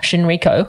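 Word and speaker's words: Shinrikyo: that was actually used by the doomsday cult Shinriko, and I have probably said that incorrectly, who experimented...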